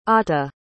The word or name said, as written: ata